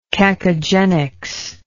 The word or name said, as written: cacogenic